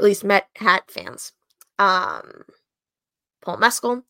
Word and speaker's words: Mescal: A picture came out yesterday, 2 days ago, of beloved actor of this movie fan, Paul Mescal, and that is how you pronounce his name, not Mezcal, Paul Mescal, being seen out in a Mets hat.